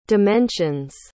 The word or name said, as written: dimensions